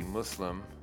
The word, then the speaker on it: Muslim: That's the best part of Rami when his cousin's like, it's a soft S, like pussy Muslim.